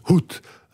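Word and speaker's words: Xhout: Ça s'écrit Xout, mais ça se prononce Hout, avec un H aspiré.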